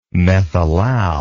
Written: methylal

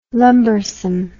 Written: lumbersome

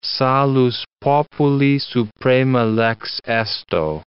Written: salus populi suprema lex esto

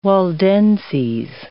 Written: Waldenses

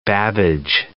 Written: Babbage

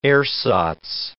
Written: ersatz

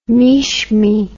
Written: Mishmi

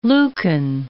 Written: Lucan

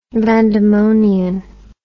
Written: vandemonian

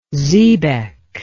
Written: zebec

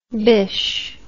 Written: bish

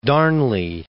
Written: Darnley

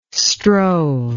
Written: strove

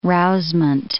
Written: rousement

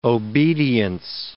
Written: obedience

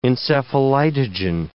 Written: encephalitogen

